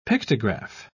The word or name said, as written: pictograph